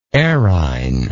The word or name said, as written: errhine